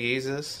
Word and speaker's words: Yeezus: Can you say Yeezus? – Yeezus. – Y-E-E-Z-U-S.